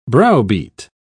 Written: browbeat

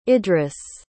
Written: idris

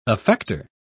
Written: effector

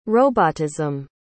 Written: robotism

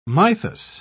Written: mythos

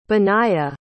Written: benaiah

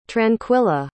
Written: tranquilla

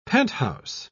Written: penthouse

